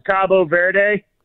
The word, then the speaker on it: Cabo Verde: They in Spanish, it's Cabo Verde.